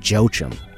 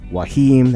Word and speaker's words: Joachim: Made the mistake of pronouncing it Joachim, but it's Wahim, that's J-O-A-C-H-I-M.